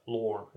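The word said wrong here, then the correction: Lorne: Is that how you say it? – Lorn. – Lorn. Lorn sausage.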